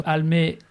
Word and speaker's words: Almé: Almé, comme ça se prononce, avec un « e », c'est important. Donc, absolument.